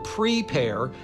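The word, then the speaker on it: Prepear: Apple is apparently taking legal action against a company called Prepare, like the word prepare, but it's spelled at the end, it's spelled P-E-A-R.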